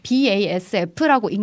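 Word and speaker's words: BASF: 사실 이게 BASF라고 읽는 것이 어. 현재에서는 맞습니다.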